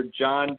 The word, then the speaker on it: Jon: If the listeners out there want to get in touch with me, I'm on all the social media platforms under John Decker Golf Instruction, and my first name is spelled J-O-N, so John Decker Golf Instruction.